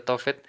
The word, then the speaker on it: Tofet: y por ejemplo encontré en un diccionario Tofet la palabra hebrea, de acuerdo con la pronunciación masorética, resulta de asignarle las consonantes TF, bueno, las vocales que quedan ese sonido Tofet y que se podría traducir en vergüenza, con lo que el término adquiere el significado de escupida o lugar aborrecible.